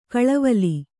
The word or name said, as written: ಖಲೇವಾಲಿ